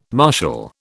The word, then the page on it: marshal: British English